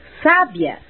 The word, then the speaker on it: sábia: Por exemplo, sábia, com acento no A, significa inteligente. Sabia, com a sílaba tônica na segunda sílaba, é do verbo saber. E sabiá, com acento na última sílaba, é o pássaro.